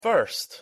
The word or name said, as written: first